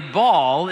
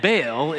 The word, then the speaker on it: Baal: Apparently, Baal is the English pronunciation, and Baal is the American pronunciation.